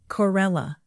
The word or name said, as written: corella